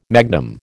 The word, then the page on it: magnum: American English